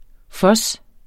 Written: fuzz

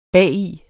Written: bagi

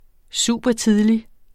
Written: supertidlig

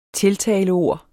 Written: tiltaleord

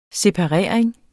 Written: separering

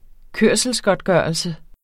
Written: kørselsgodtgørelse

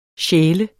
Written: sjæler

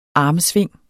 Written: armsving

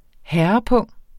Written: herrepung

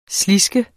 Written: sliske

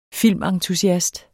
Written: filmentusiast

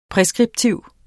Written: præskriptiv

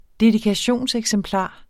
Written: dedikationseksemplar

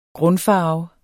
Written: grundfarve

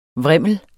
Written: VRIMLENE

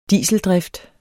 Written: dieseldrift

